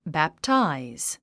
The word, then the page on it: baptize: bæptáiz; バプタイズ